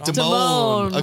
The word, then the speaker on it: Damon: That's, am I saying that right? – Damon.